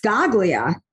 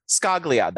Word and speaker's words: Scaglia: Now let's talk about Julia Hart and her now estranged husband, Silvio Segalia's. Their legal team has spoken out. How do I say it? – I think it's just Scaglia. – Scaglia. That looks exactly what it is.